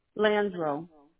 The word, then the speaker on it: Landreaux: Landro. – Landro? – L-A-N, D-R-O? – No, L-A-N-D-R-E-A-U-X.